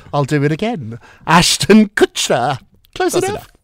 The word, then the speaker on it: Kutcher: How do you say it? – Kutcher. – Kutcher. Kutcher. Kutcher. What did I say? – Kushner.